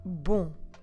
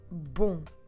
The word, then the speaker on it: bom: You obviously remember the word for good, which is bom. Bom. Como se escreve bom? B-O-M.